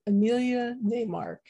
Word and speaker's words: Emilya Naymark: I'm pleased to have with me today my guest, Emilya Naymark.